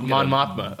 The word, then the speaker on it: Mon Mothma: Speaking of that, one of the scenes that we see him in this movie, he's talking to Moth Mothma? How do you say her name? – I would say that's pretty close. – Mon Mothma? – Mon Mothma. Okay, I had it backwards.